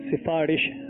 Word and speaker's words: سفارش: سفارش. سین کے نیچے زیر ہے.